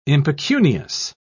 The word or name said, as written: impecunious